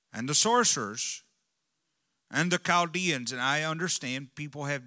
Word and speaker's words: Chaldeans: I will use what my Bible gives me as pronunciations, and that's why I call it Chal. That CH is underlined as a long K sound.